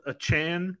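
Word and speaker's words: Achane: So, like, you can't start both Achan, which is what he says he wants to be called, Achan. – I thought it was Achan. – Well, I thought so too, but then he put the correct pronunciation as Achan.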